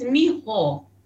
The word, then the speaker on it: mijo: M-I-J-O. – That's Miho.